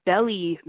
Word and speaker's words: Belyi: For example, there's a theory of Belli maps, where Belli is spelled B-E-L-Y-I.